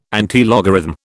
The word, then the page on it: antilogarithm: British English